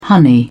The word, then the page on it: honey: British English